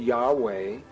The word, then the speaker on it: Yahweh: I am convinced beyond any reasonable doubt that this name, YHVH, pronounced Yahweh,